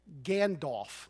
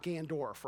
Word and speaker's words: Gandalf: Gandalf, not Gandorf, right? I always want to call him Gandorf.